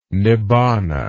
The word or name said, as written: nibbana